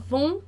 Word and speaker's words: vont: Não vai pronunciar, então não pronuncia o T, tá? Ils vont, elles vont. Lembra lá que eu falei T, D e S, né? Ils vont, elles vont, não pronuncio T, então.